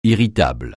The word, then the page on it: irritable: francais